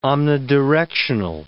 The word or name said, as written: omnidirectional